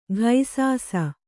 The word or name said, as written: ಘೈಸಾಸ